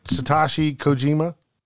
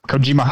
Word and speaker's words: Satoshi Kojima: Did I say that right? Satoshi Kojima? – Yep, Kojima.